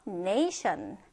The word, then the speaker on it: nation: nation. nation. 자, 지금 발음 끼고 잘 보면 슈 발음이 나요.